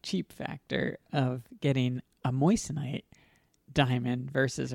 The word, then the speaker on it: moissanite: And go to moissanite. Let me read a little bit about moissanite. Am I even pronouncing it right? – Moissanite? – Yeah. – No, that is correct.